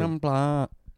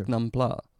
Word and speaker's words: Prik Nam Pla: Prik Nam Pla. – Säg en gång till. – Prik Nam Pla. – Ja, alltså hon upprepar det precis så som du uttalade.